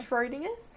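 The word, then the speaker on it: Schrödinger: Schrodinger's? – Yeah. – Is that right? – That's how you say it.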